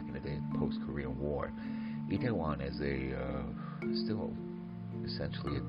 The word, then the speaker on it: Itaewon: Itaewon is an interesting area that's spelled I-T-A-E-W-O-N, Itaewon.